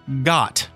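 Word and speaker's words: Goht: It is actually pronounced got.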